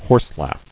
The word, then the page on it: Horselaugh: horse·laugh